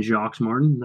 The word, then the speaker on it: Jacques Martin: and Jacques Martin.